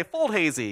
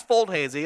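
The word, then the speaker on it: Foldhazi: And I say, Fold Hazy, you know, and they're like, what?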